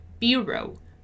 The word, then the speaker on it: bureau: Bureau, bureau, bureau. And today we have a longer word that has a different stressed syllable. Notice that in bureau, my stressed syllable is bu, bureau, bureau, bureau, right?